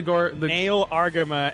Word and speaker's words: Nahel Argama: How do you pronounce that again? The Nahel Argama and Garin Sierras.